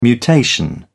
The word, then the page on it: mutation: British English